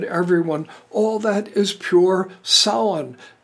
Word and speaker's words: Samhain: I'm saying it as Samhain, which is allegedly pretty close to the Gaelic. Just don't be a dork and say Sam Hain like it's spelled.